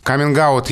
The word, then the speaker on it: каминг-аут: Каминг-аут есть, наверное, смысл произносить со звонким «г», потому что перед гласным сильная позиция и нет оснований для оглушения.